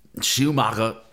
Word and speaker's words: Schumacher: I think I said it right for the Germans out there. – Schumacher.